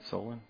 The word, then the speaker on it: Solon: Yesterday, I met some pastors and church leaders for lunch over near Cleveland in Solon. And did I say that word right? Solon? Yeah, okay.